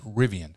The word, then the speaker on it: Rivian: And, Rivian, I'm pronouncing it right. – Rivian. – Yep.